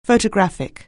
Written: photographic

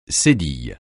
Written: cédille

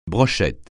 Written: brochette

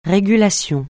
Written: régulation

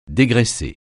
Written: dégraisser

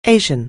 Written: Asian

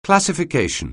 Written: classification

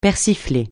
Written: persifler

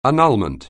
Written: annulment

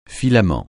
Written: filament

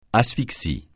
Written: asphyxie

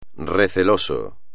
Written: receloso

